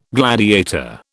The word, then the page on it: gladiator: British English